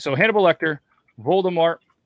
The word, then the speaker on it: Voldemort: Did I say that right? Voldemort? – Yeah. – Voldemort.